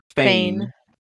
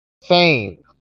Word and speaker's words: Fane: Fane. – Fane.